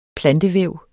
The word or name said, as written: foderplante